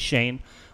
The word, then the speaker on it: Schoen: His name is Joe Schoen, I believe you pronounced it. It looks like Schoen on paper. I believe it's Schoen. We'll go with that.